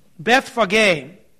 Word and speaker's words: Bethphage: I mean, literally, this is how this word should be pronounced in the Greek. Bethphage. Bethphage is how it really should be pronounced.